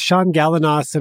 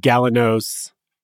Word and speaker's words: Shaun Galanos: It's Shaun Galanos, and I'm a love coach, but my dad pronounces it Galanos, and he's Australian, so...